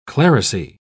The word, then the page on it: clerisy: British English